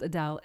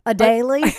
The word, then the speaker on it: Adele: And it's not pronounced Adele. It's pronounced Adele. Hey, Adele. – Adele. Adele. – Adele. – You, because phonetic wise, they say it's pronounced UH-DALE. Now, I used to teach a guy named Dale.